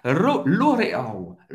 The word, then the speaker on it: L'Oréal: L'Oréal, L'Oréal em francês, L'Oréal é L'Oréal, L'Oréal. A gente vai pronunciar o L no final, L'Oréal. Vamos va agora de, de marcas de luxo, de roupas luxosas.